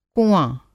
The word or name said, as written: कुँआ